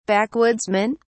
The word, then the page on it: backwoodsmen: British English